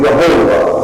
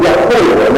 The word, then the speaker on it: Yahweh: But this is the name Jehovah. Now, you're not sure you know how to pronounce it. You see, another way to pronounce it is Yahweh. Y-O-H-E-H or W-E-H.